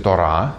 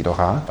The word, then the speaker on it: Тора: Тора, иногда произносится Тора, что правильнее по-еврейски Тора, Навиим и Хатувим или Катувим, как хотите.